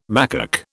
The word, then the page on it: macaque: British English